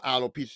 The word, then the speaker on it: alopecia: Alicia. I ain't say that right. She had the alopecia. It's called alopecia.